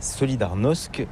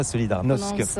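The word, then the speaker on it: Solidarność: Alors solidarité, en français on dit solidarnosc, mais ici à Gdansk on ne prononce pas solidarnosc.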